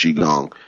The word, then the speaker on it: Qigong: When you go through some of the ancient Taoist and Buddhist methods with the Qigong energy, Qigong is the proper pronunciation. People say Qigong, but it's Qigong.